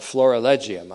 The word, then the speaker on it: florilegium: The reference to the throne in Hebrews 1:8 is at the heart of what we remember we called the florigelium, or make that the florilegium. I mispronounced that earlier, and I'm doing it again. The heart of the florilegium, that's F-L-O-R-I-L-E-G-I-U-M, you'll see it in print.